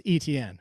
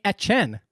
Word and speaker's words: Etienne: There shouldn't be a third syllable. It should just be Etienne if you're actually doing it right. So he's not even pronouncing it the right way either.